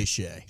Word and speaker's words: Shai: Then it was Giannis, LeBron, and Shai Gilgeous-Alexander. Or is it Shea? – I say Shea.